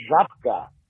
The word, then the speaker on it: Żabka: The correct pronunciation of the grocery store is Żabka. Mm. In the Polish language, Żabka. A Z with a little dot on top of it makes the Ż sound.